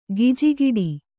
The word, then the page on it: ಗಿಜಿಗುಟ್ಟು: gijiguṭṭu; Kannada